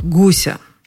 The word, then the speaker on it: гуся: Чисто по-русски С Мариной Королевой Гуся и гуся Жили у бабуси два веселых гуся. Напевая эту песенку, никаких проблем с ударением вообще не испытываешь. Один гусь, два гуся. Мальчик был сильно напуган огромным гусем. Подойди к этому гусю. Так?